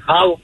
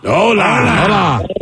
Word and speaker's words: hola: Just hola. Okay. No H. Yeah, just hola.